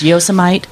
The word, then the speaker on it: Yosemite: I think it's pronounced Yosemite. – Yosemite, exactly.